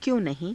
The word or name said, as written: kyo~_nahii~